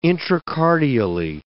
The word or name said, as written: intracardially